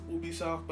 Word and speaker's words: Ubisoft: But it was smart for Google to team up with Ubisoft, whatever, how you pronounce it. People say it's French, so it's pronounced Ubisoft, but honestly, I think it doesn't matter.